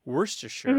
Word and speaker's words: Worcestershire: That word is so old. Worcestershire mm-hmm.